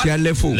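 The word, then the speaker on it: Shia LaBeouf: Esteban, y que Chiaf Lavelu. ¿Lo dije mal? – Chiaf Lefou.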